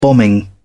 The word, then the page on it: bombing: British English